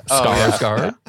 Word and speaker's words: Skarsgård: Skarsgård.